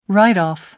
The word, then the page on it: write off: American English